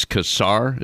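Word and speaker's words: Casar: One of your representatives, Greg, it's Kassar. Is that how you pronounce his name? – Yeah, that's it.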